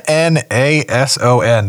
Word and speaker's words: Mnason: Mnason? – M-N... Oh my gosh. – Mnason.